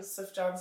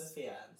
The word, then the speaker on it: Sufjan: Sufjan Stevens. – Sufjan.